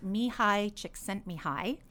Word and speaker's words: Csikszentmihalyi: And also a book called Flow by, now, this is how I've heard how to pronounce his name. It's Mihaly Csikszentmihalyi. But his first name is Mihaly.